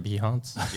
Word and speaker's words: Behance: Behance? – It is now. – Behance. I'm from Texas, y'all, so. – Behance. We're going to get on that Behance. – I'm going to get on that Behance, and I'm going to find me a retoucher.